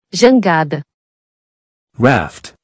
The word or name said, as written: Jangada